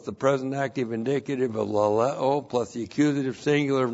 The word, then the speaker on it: laleo: Laleo. L-A-L-E, long O.